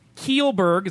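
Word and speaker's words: Kjellberg: Last year, Kielberg, is that how you say his name? – Yep.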